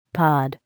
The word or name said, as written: pod